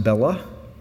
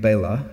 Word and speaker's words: Bela: His name is often mispronounced Bela. It's a Hungarian name, Bela.